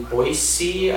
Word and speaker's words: Boise: But no, I chose to start in Boise, Idaho instead.